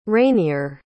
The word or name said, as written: rainier